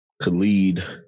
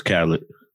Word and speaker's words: Khalid: Khalid. – Yeah. – Yeah. I always thought dude's name was Khaled. – No, I think a lot of people did.